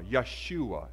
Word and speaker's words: Yeshua: The Hebrew word was Joshua, Yeshua, as the Hebrew people will pronounce it.